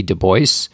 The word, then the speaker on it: Du Bois: The first one is the Library of America collected writings of W.E.B. Du Bois, which is the way he would like us to pronounce it, as far as I can. – And it's actually easier for those of us who are not French anyway.